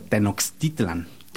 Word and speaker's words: Tenochtitlan: Porque la pronunciación, digamos, originaria debería de ser Tenochtitlan. – Tenochtitlan. – Okay. Pero la X se españoliza de cierta forma y se usa como sh, Tenochtitlan.